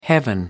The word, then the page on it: heaven: American English